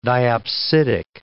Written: diopsidic

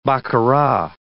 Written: baccarat

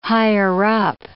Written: higher-up

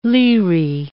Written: liri

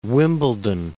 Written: Wimbledon

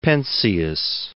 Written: Penzias

